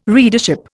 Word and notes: readership: Indian

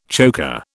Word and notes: choker: British English

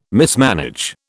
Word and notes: mismanage: British English

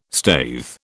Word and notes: staithe: British English

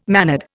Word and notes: mannered: Indian

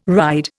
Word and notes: ride: Indian

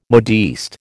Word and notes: modiste: American English

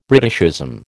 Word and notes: Britishism: American English

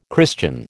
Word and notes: Christian: American English

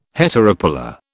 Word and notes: heteropolar: British English

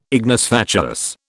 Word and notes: ignis fatuus: British English

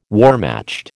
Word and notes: Wehrmacht: American English

